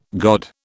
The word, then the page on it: god: British English